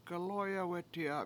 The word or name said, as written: gaLOyawEti7a